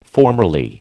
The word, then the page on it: formerly: American English